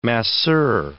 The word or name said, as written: masseur